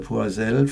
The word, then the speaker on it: Poisel: Ja, von Philippe Poisel, das ist die Marke, sein Künstlername Poisel, französisch ausgesprochen.